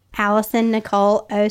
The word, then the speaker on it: Alyson Necole: Um on Instagram at Alyson Necole 06 um, my name is spelled A L Y S O N N E C O L E 06.